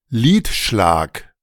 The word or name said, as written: Lidschlag